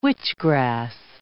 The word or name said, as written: witchgrass